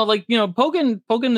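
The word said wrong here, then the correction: Pokkén: is just like a different thing, or Pokken, excuse me.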